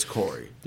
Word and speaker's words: Corey: Corey Vednik or Carey. – I don't know. We said his name wrong for like 4 weeks. – Yeah, but it was Corey.